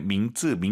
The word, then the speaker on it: 名字: 이름 발음하실 때 민츠, 민츠, 쌍지읒 아니에요. 민츠, 민츠, 이성경성.